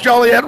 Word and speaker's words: Joliet: Get the kids, come out there to Joliet. be part of the experience. And do I pronounce Joliet right? – Yes, you did.